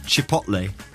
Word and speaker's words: chipotle: The correct pronunciation is chipotle.